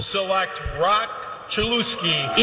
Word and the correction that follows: Chulowski: It's spelled L-O-W like cow. It's spelled L-O-W, Lau, like cow. – Chulowski. – Like, anybody would look at this name and say Chulowski.